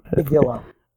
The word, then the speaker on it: amygdala: He notices that they remove a part of River's brain, and he mentions that they cut off her amalga. – Amygdala. – Oh, yeah, Amadillo. I forgot how to say it. you said it right.